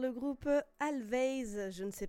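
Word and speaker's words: Alvvays: Du coup, je suis allée faire quelques recherches sur ce groupe Always, mais qui s'écrit avec deux V. Du coup, ça se prononce Always, ça me déstabilise.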